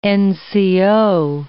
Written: NCO

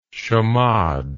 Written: chamade

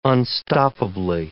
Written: unstoppably

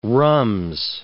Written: rhumbs

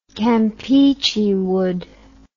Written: campeachy wood

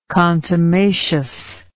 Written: contumacity

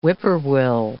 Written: whip-poor-will

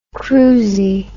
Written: cruizie

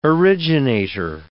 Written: originator

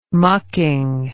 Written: mucking

